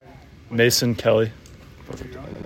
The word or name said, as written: Mason Kelley